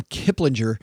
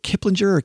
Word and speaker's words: Kiplinger: First headline comes to us from Kiplinger. I never know if it is it Kiplinger or Kiplinger. – I prefer the soft G.